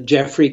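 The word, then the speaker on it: Geoffrey: I have a website, which is simply Jeffrey Cabot. My name's spelled with a G, G-E-O-F-F-R-E-Y, and last name is K-A-B-A-T, Cabot, .com.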